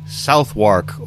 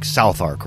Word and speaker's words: Southwark: And so your last name will be Southwark. Or if you were British, it would be like Southark, right? Wouldn't they say... They wouldn't say the Wark. I think they'd say Southark. Southark.